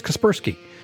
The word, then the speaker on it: Kaspersky: To the folks at Kaspersky, did I say that right? Let me take another look. Yes, Kaspersky.